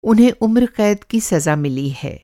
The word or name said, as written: उम्रक़ैद